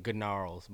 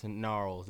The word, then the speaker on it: Gnarls: But you you search a picture of I don't know why I keep saying good gnarls, but it's gnarls. The G is silent.